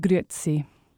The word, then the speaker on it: Grüezi: with Grüezi. – Grüezi. – Grüezi. And you really pronounce the E in it, so when you read it, the temptation is, because it's spelt G-R-U-E, or umlaut, Z-L-I, is to go Grüezi, mm-hmm.